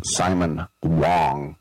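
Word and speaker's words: Simon Wang: It looks like Ho Chi Wang, but it's pronounced Simon Wong.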